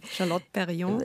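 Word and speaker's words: Charlotte Perriand: Also zum Beispiel die Bauhausfrauen, die, da haben Sie einer Person auch die auch in Ihrem Roman dann wieder vorkommt, dieser Charlotte, ich kann kein Französisch, also, Sie können es besser ja, aussprechen. – Charlotte Perrion.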